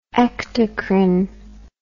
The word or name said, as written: ectocrine